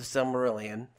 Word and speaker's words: Silmarillion: So we're going to start with the Silmarillion.